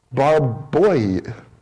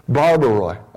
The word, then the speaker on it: barbaroi: The word translated native people in verse 2 and again in verse 4 comes from a Greek word, barbaroi. I didn't say that correctly. Barbaroi.